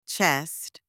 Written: chest

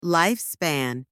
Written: lifespan

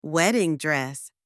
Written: wedding dress

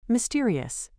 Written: mysterious

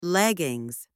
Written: leggings

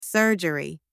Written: surgery

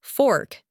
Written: fork

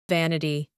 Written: vanity

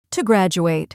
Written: to graduate

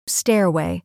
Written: stairway

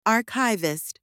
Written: archivist

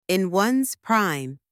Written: in one's prime

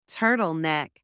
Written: turtle neck

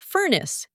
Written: furnace

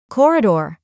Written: corridor

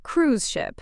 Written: cruise ship

